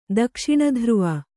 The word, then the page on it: ದಕ್ಷಿಣಧ್ರುವ: dakṣiṇa dhruva